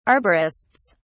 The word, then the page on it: arborists: British English